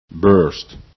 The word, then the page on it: burst: english